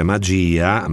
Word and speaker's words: magia: Rieccoci in diretta 12 e 6 minuti sulle note della nuova canzone di Alvaro Soler, Magia, che si ripeto si scrive magia, ma in spagnolo si pronuncia magia.